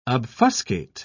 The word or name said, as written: obfuscate